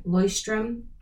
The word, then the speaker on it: Leuchtturm: It's a German brand, Leuchtturm.